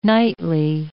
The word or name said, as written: nightly